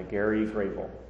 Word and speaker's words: Gary Gravel: John Carter Mars, Gods of the Forgotten by Gary Gravel. It's spelled like Geary Gravel, but it's Gary Gravel, it's pronounced.